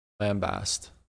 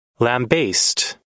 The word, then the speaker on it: lambaste: Lambast. – Lambaste.